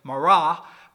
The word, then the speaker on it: Mara: Probably a different pronunciation because they tended to emphasize the second syllable, so it was probably Mara.